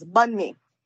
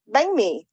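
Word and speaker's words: bánh mì: The North Vietnamese pronunciation gives that NH a G sound. So it's pronounced bang me, like, bang.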